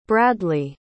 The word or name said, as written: bradleah